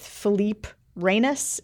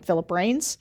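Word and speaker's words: Philippe Reines: So I was on CNN this morning with Philippe Reynes, is how you pronounce his name, apparently. I didn't know. I would have called him Philip Reynes, but it is Philippe Reynes.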